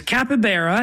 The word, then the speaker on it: capybara: Is it capybara? – I'll give it to you. It's capybara, and that is the correct answer.